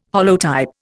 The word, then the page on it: holotype: Indian